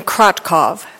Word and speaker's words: Krotkov: My name is Jasmine Krotkov. That's spelled K-R-O-T-K-O-V, and I come to you from the Montana Farmers Union.